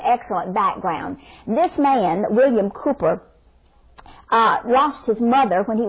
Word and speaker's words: Cowper: It's been written by a man called William Cowper. It's not pronounced Cowper, though that's how it's spelled, but it's William Cowper.